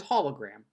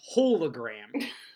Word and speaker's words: hologram: Like you, I would hope you, and I and Jocelyn, the word is hologram. There's no E, there's no, like, you know, long O sound, but sporadically throughout this series, you'll catch a character saying hologram.